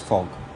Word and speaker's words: PHOG: But I think the key to it is really what I refer to as FOG. It's spelled P-H-O-G. It's P-H-O-G, which is prophecy, hearsay, opinion, and gut feel.